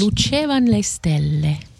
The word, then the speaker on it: Le lucevan le stelle: Is that roughly it, or Le Stellen? – Le Lucivan Le Stelle. – And, you're proper Italian. Pronounce it properly for me once. – Le Lucevan Le Stelle.